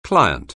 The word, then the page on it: client: anglais